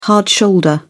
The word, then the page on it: hard shoulder: British English